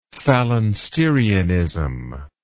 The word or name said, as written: phalansterianism